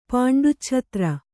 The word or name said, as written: ಪಾಂಡುಚ್ಛತ್ರ